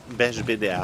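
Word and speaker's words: Beigbeder: Ah ja, Big Beder. – Big Beder. – Beige Beder. – Beige Beder. – Ja, genau. Auch toll.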